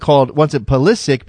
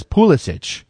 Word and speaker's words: Pulisic: Christian Pulisic wants it Pulisic because he thinks that's more American, but his grandfather says Pulisic because that's the more Croatian pronunciation.